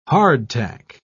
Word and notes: hardtack: British English

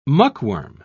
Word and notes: muckworm: British English

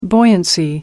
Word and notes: buoyancy: British English

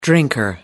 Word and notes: drinker: American English

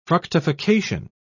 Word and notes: fructification: British English

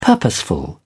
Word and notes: purposeful: British English

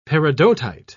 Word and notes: peridotite: British English

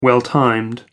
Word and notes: well-timed: American English

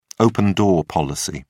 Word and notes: open-door policy: British English

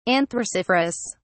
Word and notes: anthraciferous: British English

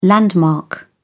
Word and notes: landmark: British English

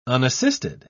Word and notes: unassisted: British English

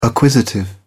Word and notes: acquisitive: British English

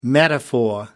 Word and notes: metaphor: American English

